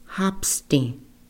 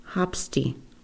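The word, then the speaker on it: Hapstíŋ: Okay, for the third born daughter within a Tiwahene family, she was named Hapsti. Hapsti. Okay, let's pronounce that together. Hapsti. Hapsti. Okay, the fourth born daughter, Wanske, Wanske, the fourth born daughter, Wanske.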